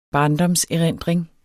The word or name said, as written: barndomserindring